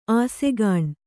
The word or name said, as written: ಆಸೆಗಾಣ್